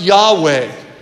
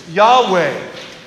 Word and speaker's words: Yahweh: Four simple Hebrew letters, Yud, Hey, Vav, Hey, probably pronounced Yahweh.